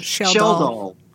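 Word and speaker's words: Schjeldahl: Sheldahl.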